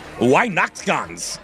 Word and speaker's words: Weihnachtsgans: Then we go to the famous German goose dish, Weihnachtsgans. I think that's how you say it. – I think you nailed that.